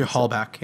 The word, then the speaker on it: Halbach: Halbach, yeah.